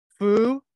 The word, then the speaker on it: Fuakilau: I am here with my friend Sau Fuakilau.